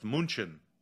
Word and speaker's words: München: If we're talking about like uh, cities that are mispronounced by Americans or English speakers, Munich is one of the biggest ones, which I've never understood why we pronounce it the way that we do when it's actually pronounced München.